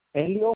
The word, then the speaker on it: Helio: Es Elio Medina Alcaraz. – Okay, ¿cómo se escribe? – ¿Cómo se escribe? Es una Sí. – H, una E, una L, una I y una O.